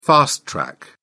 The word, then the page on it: fast track: British English